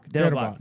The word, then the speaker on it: Dettelbach: And like, I'm not one to say whether it's Stephen Dalback. – What'd you say? – Steven Dalback? That guy? – Dettelbach. That's it.